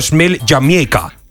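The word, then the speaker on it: Smile Jamaica: un concierto gratuito llamado Smell Jamaica, que así lo pronunciaban ellos, organizado por el gobierno con el objetivo de calmar la tensión política de los dos grandes partidos del país.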